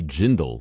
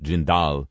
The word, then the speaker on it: Jindal: Of course, it would have been pronounced Jindal in his ancestral home of India. But for Louisiana, he kind of good old boyed it up to change the pronunciation to Jindal.